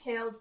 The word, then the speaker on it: Cale: I'm assuming it's like the plant. Because it's C-A-L. – C-A-L, it's Cale, yeah.